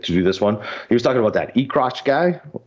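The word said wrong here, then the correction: Eckroat: I said, I know I just butchered that name. What was it? Hold on. What Ekrot. Austin. – Oh, Austin Ekrot. – Ekrot, yeah, Ekrot.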